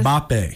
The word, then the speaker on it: Mbappe: Mbappe.